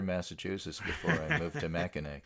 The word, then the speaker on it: Mackinac: It is that the island's name, I guess, in Michigan is pronounced Mackinac.